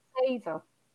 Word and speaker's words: Seidr: It's pronounced Seidr. It's spelled S-E-I-D-R, but the D is a D that sort of goes like that. And in Danish, it's what we call a blue day. It's like a soft D.